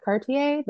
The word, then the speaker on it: Cartier: Cartier, is it Cartier, the lighter? – Yeah, it's like $1,000 for a lighter.